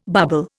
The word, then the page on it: barbel: Indian